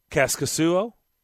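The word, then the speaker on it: Kaskisuo: And by the way, John Hines did confirm what you said, and it will be Kaskasuo. Is that how you say it? – Yeah.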